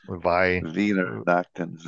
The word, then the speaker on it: Weihnachten: Well, the W Nachten. Is pronounced as a V. – Weihnachten.